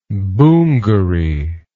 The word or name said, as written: boongary